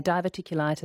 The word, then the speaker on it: diverticulitis: Di in Mandurah says, what about diverticulitis? Is that, I haven't pronounced that correctly. – Yeah, diverticulitis.